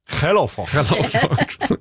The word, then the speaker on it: Yellow Fox: For jeg har nemlig begynt å høre på en sang som heter Taste for More av et orkester som heter Yellow Fox. – Jaha. – Som er fra Nederland. – Mm. – Så da uttales det... – Hello, Fox.